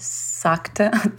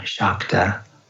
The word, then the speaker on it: Shakta: So when the S has a little accent over it in Sanskrit, it's pronounced as an SH. So it's actually Nandu Shaiva Shakta Tantra.